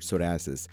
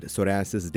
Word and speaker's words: psoriasis: So is from the word psoriasis, the first three letters, P S O, where P is silent.